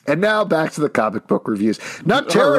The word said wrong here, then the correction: Nocterra: Did you say Nut Terra? Don't pronounce it weird. – Nocterra.